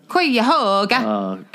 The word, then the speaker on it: Cuyahoga: April 19th, Cuyahoga Falls, Ohio. Did I pronounce that right? Cuyahoga? – Cuyahoga.